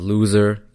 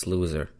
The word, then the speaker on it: Luzer: Yes, it's Luzer. It's not who I am, it's just my name. And it's spelled differently too. It's spelled L U Z E R, not L O S E R.